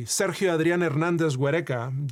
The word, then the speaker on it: Sergio Adrián Hernández Huereca: Mesa, where a 15-year-old Mexican boy, Sergio Adrián Hernández Huereca, that's how you pronounce his name in his native Spanish, was playing with friends near the border when a U.S.